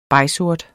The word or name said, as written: begsort